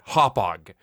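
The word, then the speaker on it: Hauppauge: It's a convention that has forced me to learn how to pronounce words such as Ronkonkoma and Hopog. Did I get that right? Did I get that right, Ken Deep? – Perfect.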